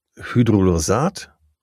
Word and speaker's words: Hydrolysat: Also das Hydrolysat oder Hydrolysat, so richtig ausgesprochen, stammt oft aus Feder-, Soja- und Hühnerprotein.